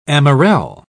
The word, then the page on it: amarelle: British English